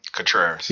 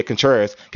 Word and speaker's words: Contreras: How do you say his name? – Contreras. – Yeah, Contreras.